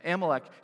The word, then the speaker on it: Amalek: Amalek, am I pronouncing that correctly? Yeah, Amalek, he was actually the grandson of Esau.